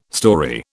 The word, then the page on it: storey: British English